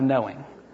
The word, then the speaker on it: knowing: And don't you wish we still pronounced the K, because then it would be three K's of knowing.